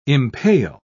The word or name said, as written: impale